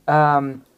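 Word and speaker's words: Quozimalz: Only I can pronounce it. Quozimaz. – Quozimaz? Can I call you Quiz? – No. – That's what Quiz. It looks like from here. – It's Q-U-O-Z-I-M-A-L-Z.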